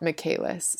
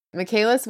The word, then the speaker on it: Michaelis: I looked it up, and I'm pretty sure it's Michaelis, but it's spelled like Michael with an IS on the end, Michaelis. But I think you pronounce it Michaelis, so that's how I'm going to say it.